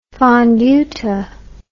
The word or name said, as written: fonduta